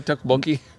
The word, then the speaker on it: tteokbokki: Am I saying it right, tteokbokki? – I think you are saying it right.